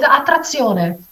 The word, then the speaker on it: Attrazione: Did I say it right? – It's Attrazione. – Attrazione. That's right. That's the pure Italian way.